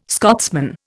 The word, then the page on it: Scotsman: Indian